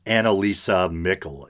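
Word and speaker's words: Anneliese Michel: I believe Annalisa Mickle is the proper pronunciation.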